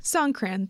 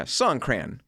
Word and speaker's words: Songkran: But Songkran? – I think that's right. – Yeah. – Songkran. That's what it looks like. S-O-N-G-K-R-A-N. – Yeah, Songkran.